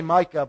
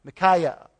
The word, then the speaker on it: Micaiah: I say Micah, but that's not right. It's Micaiah. Or who wants to pronounce that? How does that pronounce? Micaiah. I say Micaiah, that's it. Micah's wrong.